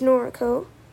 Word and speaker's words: Norco: Or is it pronounced N-O-R-C-O? It's Noriko.